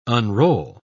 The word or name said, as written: unroll